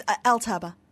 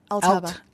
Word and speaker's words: Altaba: How do we pronounce this? Because it could be, as you pronounce it, Altaba. It also could be Altaba. – It's Altaba. Altaba.